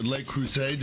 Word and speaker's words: Le Creuset: Hooman Kokabi is with Le Creuset.